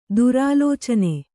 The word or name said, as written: ದುರಾಲೋಚನೆ